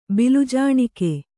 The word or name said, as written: ಬಿಲ್ಜಾಣಿಕೆ